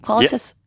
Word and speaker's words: Qualitas: And did I say that right? Qualitas? – Yeah, Qualitas. Some people say Qualitas, but we feel like it's our company, so we can say it however we want. We like Qualitas.